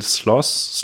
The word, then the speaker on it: Sloss: Daniel Sloss, Sloss, S-L-O-S-S, nochmal zum Mitschreiben, S-L-O-S-S. – Sloss. – Ich kann das TH aussprechen, es schreibt sich wirklich so, Sloss.